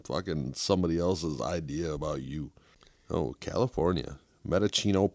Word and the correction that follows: Mendocino: Purps. – Mendocino? – Mendocino? Medicino? – Oh, we got Mendocino Greeno to smoke, too, coming up. – I think it's Mendocino.